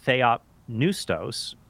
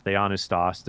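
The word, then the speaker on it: Theopneustos (θεόπνευστος): Well, the word Theopneustos, often pronounced Theonistos, there's a bit of a disagreement on how to pronounce that, but in any case, Theopneustos, the word is used in 2 Timothy 3:16, but in John 20, Christ breathes on the apostles.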